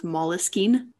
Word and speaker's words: Moleskine: Side note, did you know that it's actually supposed to be pronounced Moleskine? – No, I did not know that.